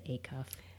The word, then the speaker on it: Acuff: Do I say his name right? Is it Acuff? – I think it is, yes.